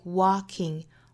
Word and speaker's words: walking: in front of the other other，美音会卷舌成 there there，最后 in the end it feels like walking walking 和 walking，美音的话是 a walking walking。我们注意 walk 这个词里面的这个字母 L 是不发音的，不是发 walking，应该是 walking，是一个单元音 walk walk。